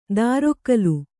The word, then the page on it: ದಾರೊಕ್ಕಲು: dārokkalu